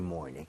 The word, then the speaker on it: morning: Every morning. 이왕이면 morning 할 때도 morning보다는 R 좀 땡겨서 more 할때 R 혀를 많이 당겨서 발음을 해보도록 하겠습니다.